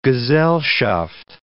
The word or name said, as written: gesellschaft